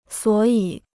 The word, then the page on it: 所以: suǒ yǐ